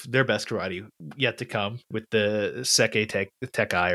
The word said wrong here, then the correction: Sekai Taikai: or whatever. – Yeah. – I'll never pronounce that correctly until they say it a million times. – Sekai Taikai. Yeah.